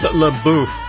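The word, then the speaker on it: La Bouche: It's French, by the way, for the mouth. And it's pronounced Labouche, apparently.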